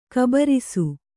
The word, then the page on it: ಘಾಬರಿಸು: ghābarisu; Kannada